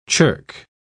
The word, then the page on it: chirk: British English